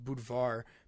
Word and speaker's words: Budvar: And I noticed that, you know, they have Budweiser over there, but it's pronounced Budvar, but it's so actually the guy, Anheuser-Busch guy, stole mm-hmm.